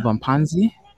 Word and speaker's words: Abaphansi: Abaphansi about Yeah, you actually got it right for the first time.